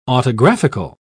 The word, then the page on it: autographical: British English